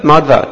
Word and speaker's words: Madhva: But Madhva, two syllables, bus.